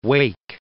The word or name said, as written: Wake Island